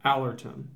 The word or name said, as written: Alorton